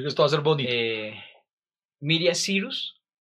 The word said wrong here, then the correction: Miley Cyrus: ¿Es así? ¿O Mila Cyrus? siempre confundo el nombre. ¿Cómo se dice verdad? Miley Cyrus. Miley Cyrus. Sí, sí. Miley Cyrus.